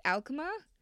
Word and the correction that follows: Alchemo: Photography is offering. – Alchemo. – Yeah. Alchemo Photography, yep.